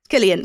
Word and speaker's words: Cillian: As I said, you've got Cillian Murphy. Or Killian, I'm not too sure how to say it. – Killian. – Cillian Murphy.